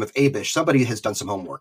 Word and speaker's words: Abish: Now, according to the pronunciation guide in the Book of Mormon, it's Abish.